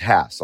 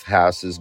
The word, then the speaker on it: Hass: And it is pronounced Haas. A lot of people say Haas. Other people say Haas. There's a debate. I got a phone call from Rudolf Haas's grandson telling me specifically how to pronounce his name. It's Haas.